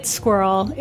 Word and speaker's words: Sqirl: for the most part pronouncing it incorrectly and having to actually tell them, no, it's Sqirl.